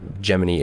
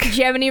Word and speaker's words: Gemini: Gemini versus Gemini. – Yeah. – Sure, go ahead. – That's it. – I actually have a video about the pronunciation because people are always like, stop saying Gemini, it's Gemini.